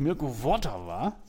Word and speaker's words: Mirko Votava: Mirko Votava, so auf der ersten Silbe, stark.